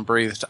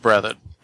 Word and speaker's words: Breathed: I was just going to say, I keep hearing you call him Breathed. I'm pretty sure the last name is pronounced Breathed.